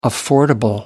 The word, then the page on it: affordable: American English